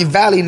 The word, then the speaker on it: Valleyy: That's all they do, call me Valleyy. – It is two syllables. I'm trying to say they call me Valleyy now.